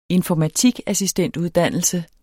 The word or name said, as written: informatikassistentuddannelse